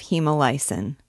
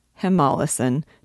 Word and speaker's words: hemolysin: Tinkering in the lab, Martin makes an accidental discovery concerning the production of hemolysin, or hemolysin, depending on what pronunciation authority you consult.